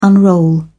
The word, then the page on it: unroll: British English